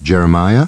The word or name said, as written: Jeremiah